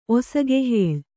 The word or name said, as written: ಒಸಗೆಹೇಳ್